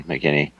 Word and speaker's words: McKinney: Yeah, it's Justin McKinney. Yeah, pretty straightforward.